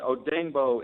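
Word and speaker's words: Odeyingbo: And Odengbo is the pronunciation.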